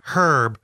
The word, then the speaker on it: herb: They don't understand how we say the word herb like it's herb. We say herb, but not herb. It's spelled H-E-R-B. We say herb, but it's herb.